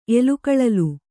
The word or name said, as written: ಎಲುಕಳಲು